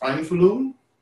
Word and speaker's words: Einfühlung: The German root, a lot of our word empathy comes from its German rooting, in a word that is pronounced Einfühlung.